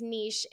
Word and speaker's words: niche: And we both agreed it's niche, and say why. I loved it. – Yeah, so it's a French word, and that would be how they pronounce it, like quiche.